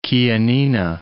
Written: Chianina

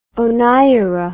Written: oneiro

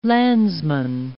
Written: landsman

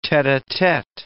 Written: tete-a-tete

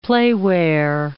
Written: playwear

